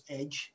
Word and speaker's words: EDJ: The details is EDJ Publishing, and if you're telling anybody, you can pronounce it as Edge. That's the whole point of it. It's an edgy production.